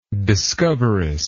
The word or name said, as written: discoverist